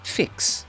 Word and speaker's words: Vicks: A V, the first letter of Vicks, is pronounced F, like an English F. Think 1, 2, 3, 4, spelt V-I-E-R. So in German, Vicks would be pronounced Fix.